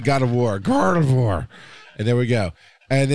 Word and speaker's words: Gardevoir: I look at Garvador or whatever her name is. I never say it right, but I know who that is. – Gardevoir. – Yeah. It's like God of War, but Gardevoir.